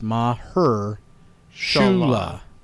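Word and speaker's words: Mahershala: Maher Shala Hashbaz. – And it's pronounced Maher Shula. Maher Shula. – Maher Shula. – Yeah. Maher Shula Hashbaz. – Emphasis on the her.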